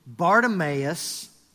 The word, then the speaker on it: Bartimaeus: Bartimaeus, which means son of Timaeus, was sitting by the roadside begging.